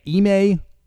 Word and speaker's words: Ime: Ime Udoka. I keep calling him Ide for some reason. I have no idea. Ime Udoka.